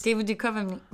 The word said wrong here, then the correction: Duchovny: But you just added an extra syllable in there. – I can't talk these days. – David Duchovny.